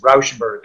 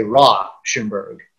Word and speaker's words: Rauschenberg: And Yeah, it's funny as I go with, say, I say my pronunciation is Rauschenberg, because that's how you pronounce it in Germany. And most Americans say Rauschenberg.